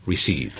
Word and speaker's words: receipt: Sí, es una palabra que lleva una P ahí en el medio, pero que no vamos a pronunciar. Es receipt.